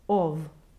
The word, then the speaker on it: of: And for any non-native English speakers, this word, OF, always is pronounced with a voiced V at the end. If that voiced consonant were unvoiced, it would sound like the word off, O-F-F.